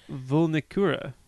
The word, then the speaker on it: Vulnicura: Vulnicura.